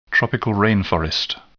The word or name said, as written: tropical rain forest